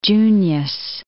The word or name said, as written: Junius